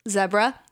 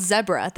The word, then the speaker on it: zebra: Zebra? Yes, guys, it's called zebra. That's how you're supposed to pronounce it. Only in the United States do we call it a zebra.